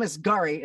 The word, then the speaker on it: Asghari: Britney and Sam Asghari.